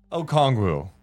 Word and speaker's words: Okongwu: Okongwu.